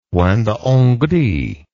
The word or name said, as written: point de hongrie